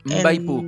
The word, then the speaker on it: Mbaipú: Mbaipú, con M al principio.